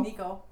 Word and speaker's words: Necco: Or Nico Wafers. Is that how you say it? Nico? – Nico. – They're not Nito.